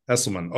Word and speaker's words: Esselman: Esselman.